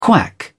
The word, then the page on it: quack: British English